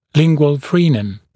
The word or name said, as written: lingual frenum